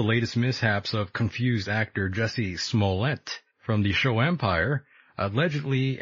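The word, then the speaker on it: Jussie Smollett: I just learned how to pronounce it the other night, so I'm like ahead of the game. It's Jesse Smollett.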